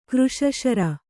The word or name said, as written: ಕೃಶಶರ